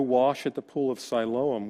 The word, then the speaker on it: Siloam: So now it has Greek declension, so it's pronounced Siloam.